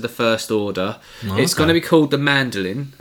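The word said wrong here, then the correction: Mandalorian: or The Mandolian, sorry, I should pronounce that correctly.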